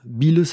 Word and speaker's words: Beale: Bill, ça s'écrit B-E-A-L-E.